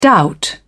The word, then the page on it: doubt: British English